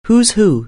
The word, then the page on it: who's who: anglais